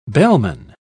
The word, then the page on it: bellman: British English